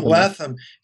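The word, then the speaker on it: Letham: Robert, and you guys probably know how to say his name better than I do, Robert Lethem. – Yeah, that's right. Yeah. – Robert Lethem,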